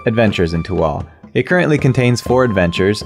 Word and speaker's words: Tehuatl: Tom is currently running a Kickstarter for one of his new projects called Tewahtl, which is spelled T-E-H-U-A-T-L.